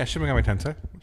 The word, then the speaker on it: Shin Megami Tensei: Shin Megami Tensei, is that what it's pronounced? – Yeah, Shin Megami Tensei. – Correct. Look at me, nailing it.